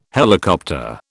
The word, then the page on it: helicopter: British English